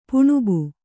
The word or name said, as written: ಪಾಣ್ಬ